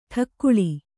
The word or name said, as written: ಟಿಕಳೆ